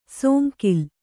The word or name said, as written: ಸೋಂಕಿಲ್